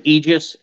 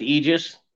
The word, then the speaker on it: Aegis: and not to correct you or anything, but there's two acceptable ways to pronounce it. There's Aegis and Aegis. And we go with Aegis, but I could care less as long as it's not something like, well, I don't even want to go down that road.